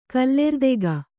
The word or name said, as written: ಕಲ್ಲೆರ್ದೆಗ